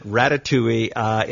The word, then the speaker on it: Ratatouille: Don't try to spell it. Just pronounce it Ratatouille.